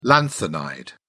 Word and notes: lanthanide: British English

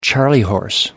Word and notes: charley horse: American English

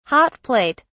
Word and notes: hot plate: British English

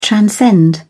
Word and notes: transcend: British English

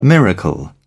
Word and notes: miracle: British English

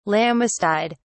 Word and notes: lammastide: British English

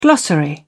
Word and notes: glossary: British English

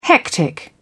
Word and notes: hectic: British English

